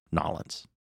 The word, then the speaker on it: New Orleans: This year it will be in New Orleans. Mm-hmm. Did I say that right? Nolens. That's how you're supposed to say it.